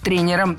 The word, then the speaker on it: тренеров: Тренеров – это неверно. Слово «тренер» имеет неподвижное ударение на основе, поэтому сохраняем это ударение на первом слоге во всех формах. Тренеров, тренером, тренерами.